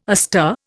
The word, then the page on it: astir: Indian